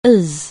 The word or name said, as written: Art Nouveau